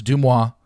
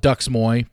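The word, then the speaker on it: DeuxMoi: For those don't know that don't understand, it's, yes, I'm very well aware, it's pronounced Dumois. I call it Dux Moy because I don't respect what they do, so I'm not going to call them by their name.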